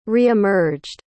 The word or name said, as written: reemerged